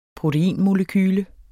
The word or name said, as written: proteinmolekyle